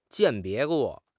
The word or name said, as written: 鉴别